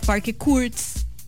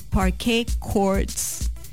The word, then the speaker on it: Parquet Courts: Yo siempre decía Parquet Courts. Después me di cuenta que es Parquet Courts.